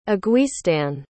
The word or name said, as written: aguistin